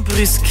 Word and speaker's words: Brussel: Het is met de U, zoals dat je Brugge niet uitspreekt. – Niet uitspreekt, het is gewoon Brusk.